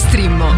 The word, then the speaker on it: Streammo: Entra adesso nel mondo di Strimmo. Vai su strimmo.it, si scrive Streammo o scarica l'app.